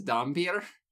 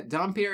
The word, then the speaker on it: Dhampir: I'm struggling not to pronounce vampir the same as Dampier, but they're distinct. – Yeah, Dampier is spelled Dhampir.